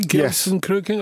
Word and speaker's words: Gelsenkirchen: Did I pronounce it correctly? Gelsenkirchen? – Yes.